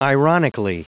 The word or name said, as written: ironically